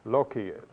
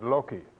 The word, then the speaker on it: loci: Loci, that's how I learned it, and Dr. Price is quite right in pronouncing it Loci. That is how they teach it in British schools.